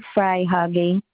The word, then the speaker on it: Freihage: MG Freihage. It's spelled F R E I H A G E.